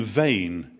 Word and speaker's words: Mheadhoin: I like informing foreigners that one of the mountains which I remember climbing as a boy is Ben Vain in the Cairngorms. And Vain is, of course, as you know, spelt M-H-E-A-D-H-O-I-N.